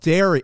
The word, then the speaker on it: Derry: Derry. It's pronounced Derry. Derry.